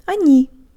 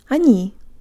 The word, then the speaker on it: они: Они. Они. Be sure to de-emphasize the first syllable and put your tongue high, combining the N with a Y sound to make it soft. Not они, but джо танг липс.